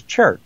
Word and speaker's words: chert: Well, how do you pronounce it anyway? – Chert. Okay. C-H-E-R-T.